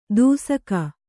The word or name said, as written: ದೂಸಕ